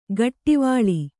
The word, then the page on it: ಗಟ್ಟಿವಾಳಿ: gaṭṭivāḷi